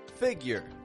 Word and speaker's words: figure: Now the common difficulty here is often pronouncing the U in the word figure with a little bit too much emphasis, a little bit too much stress, getting kind of more of a figure.